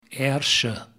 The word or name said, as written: Ayrshire